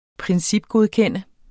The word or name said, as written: principgodkende